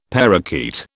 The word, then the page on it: parrakeet: British English